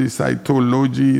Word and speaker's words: cytology: Is that psychology? Cytology? Is that what it is? It cannot be psychology. It's cytology.